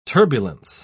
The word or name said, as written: turbulence